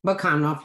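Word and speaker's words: Bakanov: Bakunov, yeah.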